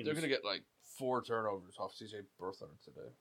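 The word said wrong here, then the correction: Beathard: Beat hard is how you say it.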